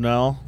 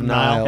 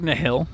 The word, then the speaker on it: Nile: You have Rivers of Nile. How do you say it? – Nile. – Rivers of Nile. – Is it Nile? – Yeah, Nile. – It looks like Nihil. – It does look Nihil.